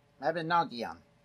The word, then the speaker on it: Mabinogion: Uh, this is the, and my pronunciation is what it is, the Mabin- Mabinogion. There we go. Ooh, I got it that time.